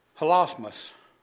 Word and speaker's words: hilasmos: Hilasmos. I think that's how you pronounce it. H-I-L-A-S-M-O-S.